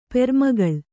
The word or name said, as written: ಪೆರ್ಮಗಳ್